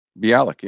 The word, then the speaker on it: Bialik: Bialik. Did I say it right? – Bialik, yeah.